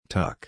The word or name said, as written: tuck